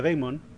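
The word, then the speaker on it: daimon (δαίμων): En religión, ocultismo y folclore, un demonio o yar del griego daimon, que se pronuncia daimon, es un ser sobrenatural descrito como algo que no es humano y que usualmente resulta malévolo.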